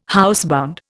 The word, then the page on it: housebound: Indian